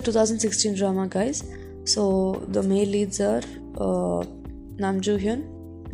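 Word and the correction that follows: Nam Joo-hyuk: Nam Joo Hyuk, I think that's the correct way to pronounce it, Nam Joo Hyuk.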